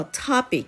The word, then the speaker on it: topic: topic ですねこの発音は最初の「 O」の部分これは「あ」に近い音になりますトピックではなく